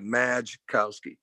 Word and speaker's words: Majkowski: Honestly, where I got that, man, it started at Fork Union, because you see, my last name has a silent J in it. Mm-hmm. It's spelled M-A-J. It looks like Majkowski, right?